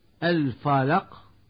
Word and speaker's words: الفلق: الفلق، ويقول من يخطئ الفلق بتفخيم حرف الفاء وعدم قلقلة القاف.